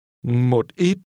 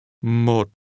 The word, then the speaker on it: Một ít: Một ít. Một. Một. Một ít. – Notice the low, falling, broken tone in the first syllable.